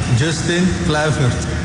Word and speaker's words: Justin Kluivert: Justin Kluivert.